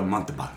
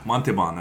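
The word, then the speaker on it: Montalbán: Ricardo Montalban. – Montalban, that's right.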